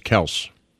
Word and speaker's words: Kelce: The real way you pronounce Kelce is Kels.